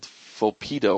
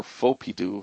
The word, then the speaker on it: Fopydo: So the one I recommend for that is by a company called Fopido, or Fopido, however you want to pronounce it.